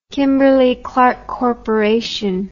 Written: kimberly clark corp

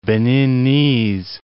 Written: Beninese

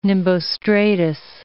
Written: nimbostratus

